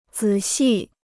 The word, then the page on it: 仔细: zǐ xì